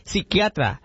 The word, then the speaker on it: psiquiatra: Y le recuerdo, oiga, no es psiquiatra, se dice psiquiatra. La P no se pronuncia.